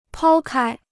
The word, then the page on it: 抛开: pāo kāi